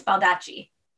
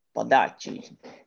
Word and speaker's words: Baldacci: Is it David Bald? I can't remember, Baldacci? – I don't know, how do you say it? Yeah, but no, it's Baldacci. – Baldacci.